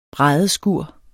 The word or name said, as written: bræddeskur